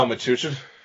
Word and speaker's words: Metuchen: He went to St. Joseph High School in, how do you say that, Metochen, New Jersey? Metuchen? – Oh, Metuchen. – Metuchen, New Jersey.